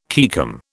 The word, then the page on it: caecum: British English